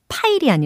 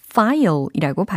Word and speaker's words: file: 파일이 아니라 file이라고 발음을 해주셔야 되겠습니다. They glanced through the file.